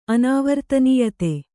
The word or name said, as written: ಅನಾವರ್ತನೀಯತೆ